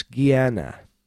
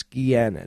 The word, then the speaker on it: Gehenna: And that's Gehenna. It's Gehenna. It's spelled G-E-H-E-N-N-A. G-E-H-E-N-N-A, or Gehenna.